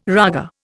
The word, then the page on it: raga: Indian